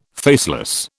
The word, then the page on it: faceless: British English